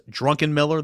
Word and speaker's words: Drunkenmiller: Yes, Drunkenmiller. That's how you say it, from Southern Miss.